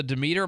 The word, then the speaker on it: Demeter: The last voyage of the Demeter, am I saying this right? – Yes.